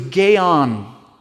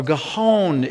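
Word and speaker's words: gaon (גאון): Leviticus 26, 19, the word pride in the Hebrew is gaon. Or gahon is, it would be a way of pronouncing it.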